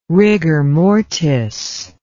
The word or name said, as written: rigor mortis